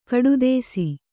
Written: ಕಡುದೇಸಿ

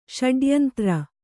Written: ಷಡ್ಯಂತ್ರ

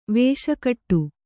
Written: ವೇಷಕಟ್ಟು

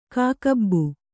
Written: ಕಾಕಬ್ಬು

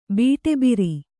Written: ಬೀಟೆಬಿರಿ

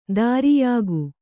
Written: ಧೌರೇಯಕ

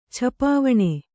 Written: ಛಪಾವಣೆ

